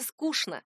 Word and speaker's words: скучно: Ну а если вы хотите просто следовать литературной норме, говорите «скучно».